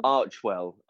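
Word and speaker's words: Archewell: So when I said the Archwell, is that, do we know how to pronounce it? Is it Archwell, Archerwell? – Archwell. Archwell.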